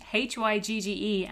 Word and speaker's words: hygge: For anyone listening, H Y G G E, and it's pronounced hygge. Maybe my Danish friends might correct me on that, but that's as good yeah, as I'm going to get.